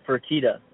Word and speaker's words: Fertitta: People like Dana White, if anybody's familiar with the UFC president, Dana White, the UFC's co-owner, Frank Ferretta, that's his name, I'm pronouncing that correctly? – Yeah, Frank or Lorenzo Fertitta, yeah, they're the owners.